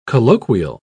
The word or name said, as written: colloquial